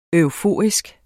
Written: EUFORIEN

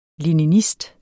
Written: leninist

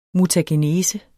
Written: mutagenese

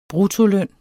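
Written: bruttoløn

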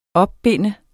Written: opbinde

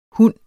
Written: hund

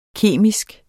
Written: KEMISK

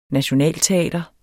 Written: nationalteater

